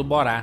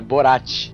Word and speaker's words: Borat: Ele tá com a cueca do Borá. – Do Borá. Por que vocês falam Borá, mano? É Borat.